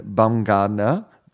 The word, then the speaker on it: Bumgarner: And one of them was, and I might not be saying his name right, but a pitcher by the name of Madison Bumgarner. Anyway, he... – That sounds right.